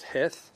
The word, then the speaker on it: Heth: What is it pronounced? Heth.